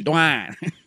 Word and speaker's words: Dwyane: Well, how does phonetically that make sense? Sound it out. D-W-Y-A-N-E. – Dwayne. It doesn't make any sense.